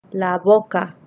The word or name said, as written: la boca